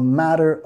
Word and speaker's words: matter: Matter, okay, enfatizando en la primera sílaba, nota la R suave, matter, no estoy diciendo matter, más matter, matter, matter of opinion, matter of opinion.